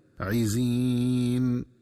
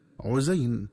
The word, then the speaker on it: عزين: وكذلك ينبغي التنبه للنطق الصحيح لكلمة عزين، فتنطق بكسر العين وكسر الزاي وياء مدية هكذا عزين، ومن الخطأ قراءتها بضم العين وفتح الزاي وياء لينة هكذا عزين.